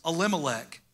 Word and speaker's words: Elimelech: The man's name was Elimelech, and I said that right.